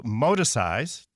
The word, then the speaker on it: Moticise: I know that you have a special term that you're using for it called modicize. Did I say that correctly? – You did. That was good.